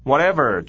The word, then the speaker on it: whatever: 再来是 Jack 说的第三句话说，Well, whatever suits your fancy, whatever, W H H 不发音，T E 的那个 T 念作的，whatever 重音在后面，whatever suits your fancy, suits T S 念作呲的声音，加上 your suits your。